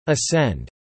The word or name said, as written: Ascend